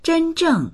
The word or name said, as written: zhen1zheng4